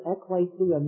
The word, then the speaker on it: ekklesia: The word church in the New Testament is the translation of a Greek word, ekklesia. And I'll spell that in English for those of you who are interested. It's spelled E-K-K-L-E-S-I-A, ekklesia.